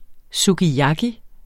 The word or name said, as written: sukiyaki